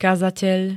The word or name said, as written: kazateľ